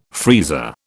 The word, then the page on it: freezer: British English